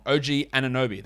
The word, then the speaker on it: OG Anunoby: While the Jedi, OG Anunoby. I think I said that wrong. OG Anunoby. That sounds better.